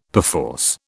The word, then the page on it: perforce: British English